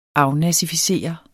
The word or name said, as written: afnazificere